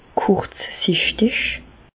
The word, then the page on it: kùrzsichtig: Bas-Rhin; Reichshoffen